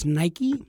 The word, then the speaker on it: Nikkei: I'm not sure what the Japanese pronunciation would be, but there is apparently some, I believe it's Nike. – Nike. – Yeah, I think you're just saying it incorrectly.